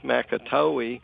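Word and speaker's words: Macatawa: I recently went down to Holland, Michigan, and was trying to figure out how to pronounce the lake down there, and it's Makatoe.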